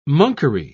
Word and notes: monkery: British English

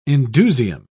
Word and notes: indusium: British English

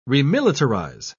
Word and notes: remilitarize: British English

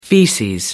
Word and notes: feces: American English

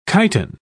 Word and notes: chiton: British English

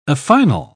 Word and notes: affinal: English; British English

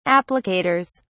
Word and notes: applicators: British English

